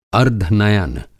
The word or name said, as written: अर्द्धनयन